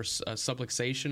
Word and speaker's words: subluxation: And then also Matt Waletzko, offensive tackle, who has not played all season, suffered a shoulder subluxation.